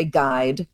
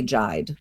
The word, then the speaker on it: guide: When we say guide, the only thing the U is doing is that diacritical role of making us certain that we shouldn't say jide, right? We say guide, we don't say jide.